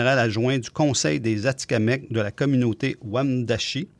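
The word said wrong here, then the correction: Wemotaci: Si j'ai bien prononcé. – Wamondashi.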